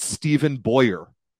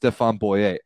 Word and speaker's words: Stéphane Boyer: I can't listen to people pronounce it Stephen Boyer when he's a Frenchman who it is clearly pronounced Stéphane Boyer.